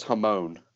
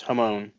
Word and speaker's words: hamon: I always thought it was a hamon. – No, it's hamon. – Oh, okay. – It's hamon.